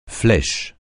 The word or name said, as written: flèche